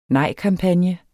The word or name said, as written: nejkampagne